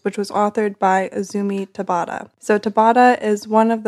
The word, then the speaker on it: Tabata: Okay, so I just quickly Googled it and I think it's pronounced Tabata. So we're just going to go with Tabata.